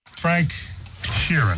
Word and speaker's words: Frank Sheeran: Frank Sheeran.